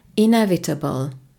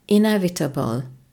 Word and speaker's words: inevitable: Kiejtése inevitable, inevitable. A hangsúly a második szótagon van.